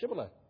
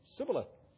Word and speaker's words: Shibboleth: If he said no, they would say to him, then say Shibboleth. And he would say Sibboleth, for he couldn't pronounce it right.